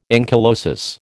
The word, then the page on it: ankylosis: American English